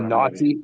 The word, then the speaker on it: KNXTTI: I don't know if you've heard of him or Naughty. – Naughty. This is another, another is his artist name. It's spelled like K N X T T I, naughty.